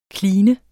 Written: kline

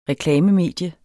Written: reklamemedie

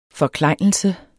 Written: forklejnelse

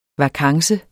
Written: vakance

